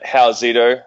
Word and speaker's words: Howsito: So folks can find me at Howzito, and it's spelled H-O-W-S-I-T-O.